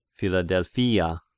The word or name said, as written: Philadelphia